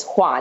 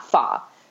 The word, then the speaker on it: Hua: Like, they did a good job changing her name because, like, the Mandarin pronunciation is Hua, not Fa.